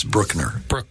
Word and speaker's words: Bruckner: Yes, Bruckner. – Bruckner.